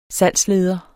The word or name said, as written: salgsleder